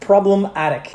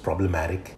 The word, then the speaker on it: Problem Attic: Brooklyn's the spent priest deliver their rarest statement yet with Problem Attic, a blunt force alt rock reckoning. – I think it's supposed to be pronounced problematic. – What did I say? – Problem Attic. Problematic. Like more fluent.